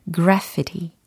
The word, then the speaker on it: graffiti: 네, graffiti라는 단어의 발음이었습니다. 어, 중력이라는 gravity가 아니고 graffiti, graffiti. 네, 이렇게 발음이 된 거고요.